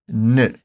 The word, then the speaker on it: eine: Por isso, nessa frase, especificamente a palavrinha eine pode ser pronunciada de duas maneiras. Se você quiser soar mais polido, pode pronunciar direitinho o eine. Entretanto, quando os nativos falam mais rápido, é bem comum eles comerem esse I do começo da palavra e acabam falando apenas ne.